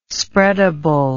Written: spreadable

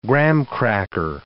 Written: graham cracker

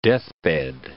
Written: deathbed

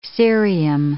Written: cerium

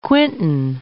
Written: quintain

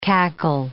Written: cackle

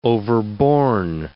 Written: overborne

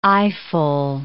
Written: eyeful

